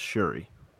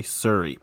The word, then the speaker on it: Shuri: I always in my head think that I'm pronouncing it wrong, but it's Shuri. – It is. – That is correct. – I keep wanting to say Suri, but it's Shuri with an H.